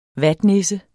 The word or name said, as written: vatnisse